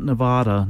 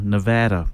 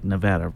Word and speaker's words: Nevada: Not Nevada, Nevada. People say it right, Nevada. Repeat after me, Nevada. Like Buena Vista, not Buena Vista.